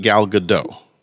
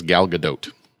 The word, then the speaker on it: Gadot: She says the O is pronounced like boat, but with a D. So she says Gal Gadot.